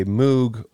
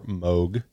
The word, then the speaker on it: Moog: I myself have a Moog or Moog, as they call them in the United States. I prefer the British pronunciation because of the Beatles.